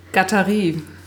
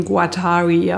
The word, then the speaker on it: Guattari: Die Amerikaner sprechen ihn Guattari aus. Aber er ist Franzose.